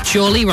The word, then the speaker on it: Chorley: Chorley rhymes with poorly, which is one way of describing my eye.